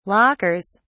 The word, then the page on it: lockers: British English